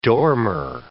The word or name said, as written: dormer